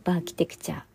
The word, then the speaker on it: バーキテクチャ: 頭に B がついてバーキテクチャって読むんですよね。